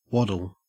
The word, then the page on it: waddle: Australian English